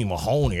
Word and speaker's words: Mahoney: He'd be like, Stony Mahoney. It rhymes with baloney.